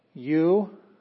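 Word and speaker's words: euangelion (εὐαγγέλιον): Well, this is euangelion. Euang, and it's ng sound here. Euangelion. So pronounce the G there, gelion.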